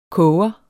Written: koger

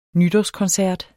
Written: nytårskoncert

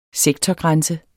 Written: sektorgrænse

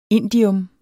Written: indium

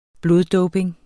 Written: bloddoping

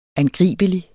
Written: angribelig

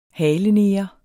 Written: haleneger

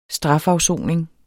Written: strafafsoning

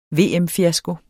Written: VM-fiasko

